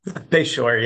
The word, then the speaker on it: Beshore: Beshore, yeah.